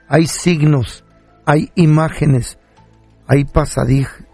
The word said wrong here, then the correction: pasadizos: ¿Cómo se dice? – Pasadizos. – Iba a decir pasadizos, son pasadizos secretos.